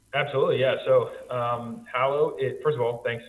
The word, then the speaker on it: Hallow: So tell us a little bit about what is this Hallow, and I think I'm saying it right. Like hallowed be thy name, right? – That is perfect.